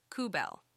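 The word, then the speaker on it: kubell: The company has decided to change its name to Kubel, pronounced as Kubel.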